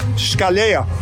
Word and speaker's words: Scalea: Scalea.